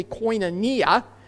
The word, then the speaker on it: koinonia: Well, the secular understanding of fellowship, which comes from the Greek word koinonia, maybe you've heard it pronounced koinonia, it's actually koinonia.